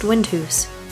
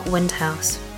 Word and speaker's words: Windhouse: Windhouse, is pronounced Windhoose, not Windhouse, because you might just get your head smashed in.